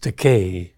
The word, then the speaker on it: Takei: Many people mispronounce my surname. They call me Takei. So this is my opportunity to correct them. It's pronounced Takei.